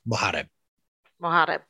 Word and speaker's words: Mohareb: Justin Mohareb. – Mohareb. Yes. – Okay. Yes. Say it fast. Say your name. – Mohareb.